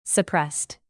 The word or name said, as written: suppressed